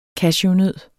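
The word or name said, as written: CASHEWNØDDER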